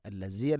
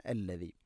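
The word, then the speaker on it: അല്ലദി (الذي): ഹുവല്ലാഹുല്ലദീ ഹുവ അല്ലാഹു അല്ലദി അല്ലദി, ദാലിൻ്റെ ഉച്ചാരണം ശ്രദ്ധിക്കുക. അല്ലസി അല്ല അല്ലദി.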